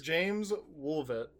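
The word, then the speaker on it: Jaimz Woolvett: Jaime's Wolf? Wolvet? – James Wolvet. – Wolvet, okay. Sorry, he has like his French pronunciation of his first name, and it's weird.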